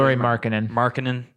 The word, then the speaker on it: Lauri Markkanen: Lauri Markkanen. Am I saying that correctly? – Lauri Markkanen.